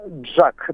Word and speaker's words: JAC: А, это китайские автомобили концерна, не знаю, как правильно это произносится, JAC? – Э, Джак, да, mm-hmm. – они, они называют это так.